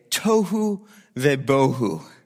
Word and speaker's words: tohu vebohu: So formless, this is in Hebrew you say tohu vebohu.